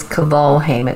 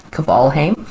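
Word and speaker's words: Kvalheim: You can look me up at Simone, S-I-M-O-N-E. And the last name is Cavalheim. It's K-V-A-L-H-E-I-M for mother, Cavalheim.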